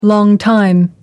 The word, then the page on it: longtime: British English